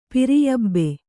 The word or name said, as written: ಪಿರಿಯಬ್ಬೆ